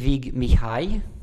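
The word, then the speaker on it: Vig Mihály: Und du hast, ich werde es natürlich falsch aussprechen, aber du kannst mich korrigieren, Vic Mihaly mitgebracht. Ist das richtig ausgesprochen? – Fast. Ja, aber Ungarisch ist nicht die leichteste Sprache. Definitiv. In diesem Sinne, in keinem Sinne eigentlich. Ja, er ist Vig Mihai.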